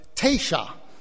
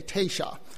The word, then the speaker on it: Taysha: I should mention the Hassanai refer to themselves as friends, and their word for friends was Tasha, usually rendered T-A-Y-S-H-A, Tasha. Of course, when the Spanish rendered that in their documents, the long A sound, the te in Spanish, was spelled with an E, and the sh sound in 18th century Spanish was with an X. So when they pluralized that, it came out T-E-X-A-S, and that's where, as you might know, we get the word Texas.